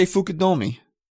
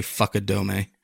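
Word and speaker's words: Fukudome: He was supposed to be better than I remember the name, because I think he used to say Fukudomi. – No, it was his name actually. If you said it phonetically, it would be Fuck You Do Me.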